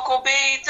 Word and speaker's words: Gobe: Is it Gobe or Gobe? G-O-B-E? – Gobe.